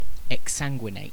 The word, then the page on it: exsanguinate: British English